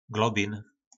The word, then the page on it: globin: po nasimu